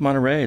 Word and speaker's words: Monterey: The 1950 Mercury Montera, is that how it's pronounced? Monterey? – I think Monterey. It's Monterey Coupe.